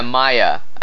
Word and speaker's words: Mire: The Mire. – Maya? – Oh, yeah, Maya. It's spelled M-I-R-E on TARDIS Wikia.